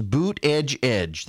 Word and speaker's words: Buttigieg: kept telling people it is boot edge edge.